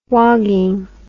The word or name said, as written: wogging